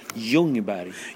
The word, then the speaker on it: Ljungberg: Yes, sorry about How do you pronounce it? – Ljungberg. Ljungberg. Yeah.